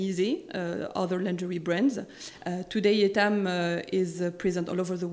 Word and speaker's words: Etam: So you are pronouncing it in the right way, actually. – I made the effort. – The French way, it's Etam.